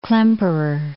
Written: Klemperer

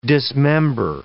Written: dismember

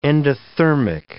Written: endothermic

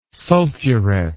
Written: sulfuret

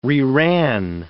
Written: reran

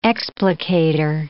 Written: explicator

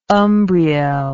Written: Umbriel

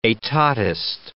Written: etatist

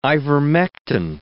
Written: ivermectin

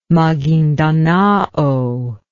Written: magindanao

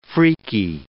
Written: freaky

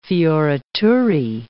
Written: fioriture

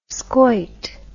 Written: schuit

